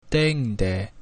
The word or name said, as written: deinde